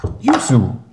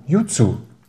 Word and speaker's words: Yuzu: Das heißt Yuzu. Yuzu.